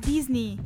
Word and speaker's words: Disney: Prima cosa scioccante, non si dice Disney? – È Disney. – Ecco, pronunciatelo Disney. Bene.